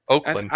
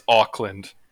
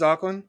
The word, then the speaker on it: Auckland: So in fact, it is Auckland, right? – It is Auckland.